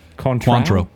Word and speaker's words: Cointreau: Cointreau, is that right? – Cointreau. – Cointreau? – Cointreau.